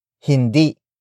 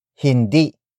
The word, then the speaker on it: Hindi: The first word is hindi. Hindi. Hindi. Which is spelled as H-I-N-D-I. It means no. The next word is isda. Isda. Isda. Isda is spelled as I-S-D-A.